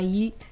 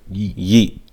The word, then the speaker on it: Yeat: Rich Minion by Yeet. – Rich Minion by Yeezy? – Yeet. – Yeet. Spell that. – Y-E-A-T.